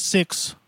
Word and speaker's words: Syxx: And then when he got to WCW, it was Six. – Six? – I guess that's how you say it. S-Y-X-X.